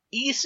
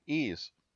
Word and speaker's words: Ys: the title pronounced. Ys is spelled capital Y lowercase s. That's it. – Yeah, Ys.